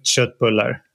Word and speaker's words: köttbullar: I hope I pronounced Köttbullar correctly. – Köttbullar.